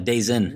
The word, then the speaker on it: DAZN: It's pronounced DAZN, but it's DAZN. Sounds like days in.